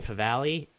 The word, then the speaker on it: Favale: If you want to talk to me on Twitter, I'm at Dan Favale, F-A-V-A-L-E.